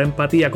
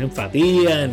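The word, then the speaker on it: Emphatía: Y si ven en nuestra marca, van a ver que tiene acento en la I. Empatía. no es enfatía, ni es empatía, ni es empatía.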